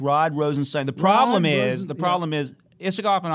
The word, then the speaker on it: Rosenstein: But the guy in charge of the Mueller investigation is Deputy Attorney General Rod Rosenstein. – Stein. I was going to say, this is going to be the podcast where we, you know, make sure people understand how these names are pronounced.